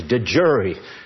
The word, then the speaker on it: de jure: The Latin is de jure. That's what it means, de jure. And so don't let the other lawyers snow you. They're saying it wrong. It's de jure.